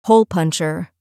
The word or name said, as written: hole puncher